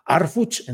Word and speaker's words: Harfuch: Bueno, eh, eh, em, híjole, a ver, Guillermo Manzano dice, en el apellido materno de Omar no se debe pronunciar la H. Ah, bueno, déjeme checar, a lo mejor Arfuch, entonces Omar García Arfuch vamos viendo, vamos viendo y vamos comentando de todo esto.